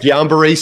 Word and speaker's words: Guillain-Barré: And then in 2021, when you were diagnosed, and I hope I'm saying this correctly, Guillain-Barré syndrome, or GBS for short. – I go GBS. Yes. Right. Guillain-Barré syndrome, Guillain-Barré, as you said it too. I've heard it that way also.